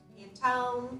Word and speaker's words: Pantone: Like I love to look at what the new color trends are and Pantone. Is that how you say it? – Yeah.